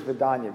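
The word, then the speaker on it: vedanā: Did you refer to that as a V-E-D or Vida? Just could you spell that word? – V-I-N-A-N-A. – V-I-N-A-N-A. – The Sanskrit is Vedanya, V-E-D-A-N-A.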